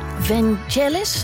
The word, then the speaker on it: Vangelis: Vangelis, zeg ik dat goed? Mm-hmm.